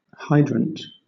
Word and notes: hydrant: British English

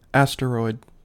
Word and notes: asteroid: American English

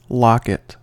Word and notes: locket: American English

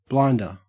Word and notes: blinder: Australian English

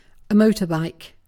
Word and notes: motorbike: British English

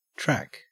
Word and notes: track: Australian English

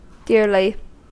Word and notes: dearly: American English

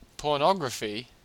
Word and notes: pornography: Australian English

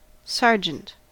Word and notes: sergeant: American English

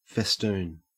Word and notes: festoon: Australian English

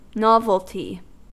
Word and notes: novelty: American English

